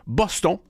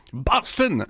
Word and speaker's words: Boston: Voici Boston, ou si vous préférez, comme elle va le prononcer, Boston, au 99.5 Montréal.